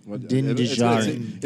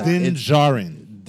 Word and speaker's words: Djarin: I have just been calling him It's, Din Djarin. Din Djarin or Din Djarin? Din Djarin. I think it's Din Djarin. The D is the D is silent, but I think it's Din Djarin.